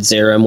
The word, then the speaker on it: Zeiram: Do you talk about, is it Zeiram or Zeiram? I don't know how to say this stuff. – Zeiram? Yeah, we talked about it quite a bit.